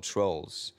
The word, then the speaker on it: Troels: It's spelled T-R-O-E-L-S. – Okay. – In Danish, you would just say trolls.